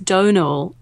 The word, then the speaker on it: Domhnall: Like, Domhnall Gleeson is pronounced Donal.